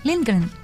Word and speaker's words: Lindgren: Yo estoy seguro que eso de Lindgren no se pronuncia así en sueco, seguramente de otra manera. – Sí, Lindgren. – Sí, es así, tal cual.